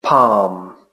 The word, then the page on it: palm: British English